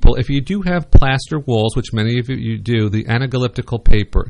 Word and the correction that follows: Anaglypta: Did I say that right? That's anaglypta. – Oh, okay. Leave it to a librarian. Okay. So thank you. I stand corrected.